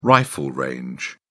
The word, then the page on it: rifle range: British English